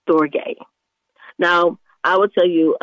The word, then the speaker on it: storge: The next type of love, it's S-T-O-R-G-E, and it's pronounced storge.